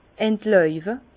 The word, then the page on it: entlauwe: Bas-Rhin; Herrlisheim